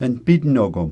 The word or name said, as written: npitnokom